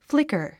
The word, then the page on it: flicker: flíkər; フリカァ